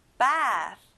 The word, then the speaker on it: bath: 친구들, 끝에 s 발음이 아니라 th, f 발음이에요. 입에서 바람이 느껴져야 돼요. bath.